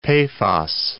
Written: Paphos

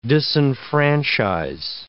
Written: disenfranchise

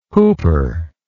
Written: hooper

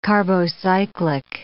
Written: carbocyclic

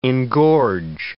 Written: engorge

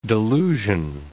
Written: delusion

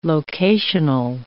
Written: locational

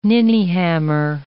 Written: ninnyhammer

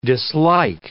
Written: dislike

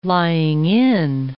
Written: lying-in

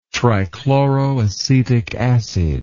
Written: trichloroacetic acid

